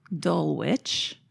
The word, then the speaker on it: Dulwich: Dulwich. – Which is spelled Dulwich.